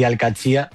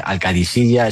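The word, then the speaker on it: Al-Qadsiah: Bueno, se suele decir Al-Qadsiya, pero también hay gente que lo dice Al-Qadisiya. Es que es difícil, ¿eh? Ah. No es fácil, pero Al-Qadsiya es lo más fácil.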